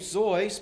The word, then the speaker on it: Seuss: Dr. Seuss, the middle name, Zeus, pronounced in the German, is spelled S E U S S.